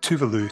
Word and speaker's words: Tove Lo: So today we're going to play with Habits, in brackets, Stay High by Tove Lo, or Tuvalu, to correctly pronounce it.